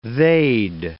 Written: they'd

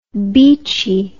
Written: beachie

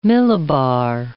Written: millibar

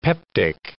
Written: peptic

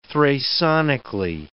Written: thrasonically

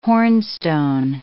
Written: hornstone

